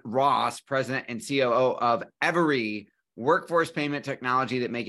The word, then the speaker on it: Everee: Yeah, that's how you pronounce it, Every.